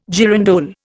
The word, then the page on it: girandole: Indian